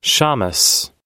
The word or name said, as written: shammes